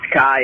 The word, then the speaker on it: Kai: Hey, Danny and Jeff, it's Kai again. My name is spelled K-A-I.